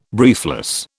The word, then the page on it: briefless: British English